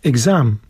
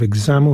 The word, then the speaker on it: exam: And also we have words like exam. Nie mówi się exam, tylko mówi się exam, no, czyli druga, druga sylaba.